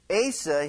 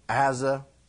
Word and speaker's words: Asa: A-S-A. You could pronounce it Asa. You could pronounce it Asa, Asa. I prefer Asa.